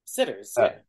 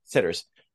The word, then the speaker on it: Citters: Daryl Van Sitters, Citters. How do you pronounce that? – Sitters. – Sitters.